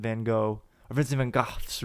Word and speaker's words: Van Gogh: There's this one, it was called The Starry Night, and you're like in Vincent Van Gogh's room. That's how you actually pronounce it, you know, for you cultured people out there.